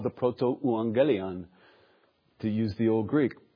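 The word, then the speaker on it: protoevangelium: So it's pronounced proto-euangelion, or sometimes it's pronounced proto-evangelium, because the you flip into a V in English.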